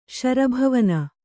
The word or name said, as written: ಶರಭವನ